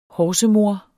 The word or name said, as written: horsemor